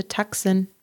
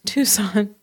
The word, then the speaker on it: Tucson: But thankfully, they were able to get a flight out of Nogales to Tucson. Tucson. I always do that.